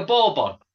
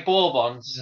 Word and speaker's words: Bourbon: I just don't put as much emphasis on the bon. – Why not? Go on, you didn't even have a go. – I would just say bourbon. – Fair enough. Well, I call them bourbon.